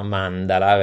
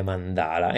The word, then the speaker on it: mandala: Innanzitutto ci tengo a specificare appunto la corretta pronuncia mandala perché ovviamente per noi italiani sembra avere già un riferimento e quindi viene spesso da definire mandala e invece l'accento è proprio sulla prima A perché ho scelto questa parola?